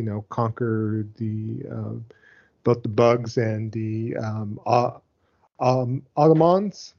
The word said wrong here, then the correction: automatons: Is how you say automatons. – That? Automatons. There you go on there.